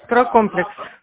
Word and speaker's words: Стройкомплекс: Я звоню компанию «Стройкомплекс». Правильно попала? – «Стройкомплекс» вы попали. – А? – «Стройкомплекс» вы попали. – А, «Стройкомплекс». Неправильно ударение поставила, извините, пожалуйста.